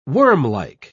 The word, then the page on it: wormlike: British English